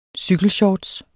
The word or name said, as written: CYKELSHORTS